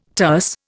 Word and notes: terse: Indian

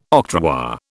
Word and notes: octroi: British English

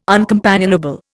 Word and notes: uncompanionable: Indian

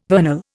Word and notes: vernal: Indian

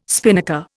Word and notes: spinnaker: Indian

